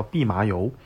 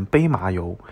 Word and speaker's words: 蓖麻油: 我印象非常深刻，就是初中物理里面有一种东西叫蓖麻油。 – 嗯嗯，然后就是就会问油跟水的这种密度之类的，他永远念成杯麻油，